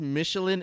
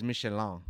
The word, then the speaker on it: Michelin: So yes, Michelin is the proper English pronunciation. – Yeah. – But the French one is Michelin.